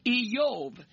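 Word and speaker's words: Eyob (איוב): For example, the name Job is pronounced this way in Hebrew, Eyob.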